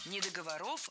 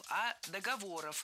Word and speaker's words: договор: Не договоров, а договоров. Вспоминаем тему выпуска. Существительные с неподвижным ударением на основе. И это значит, что ударение в слове «договор» не двигается, всегда остается на третьем слоге. Договоры, договоров, договорами.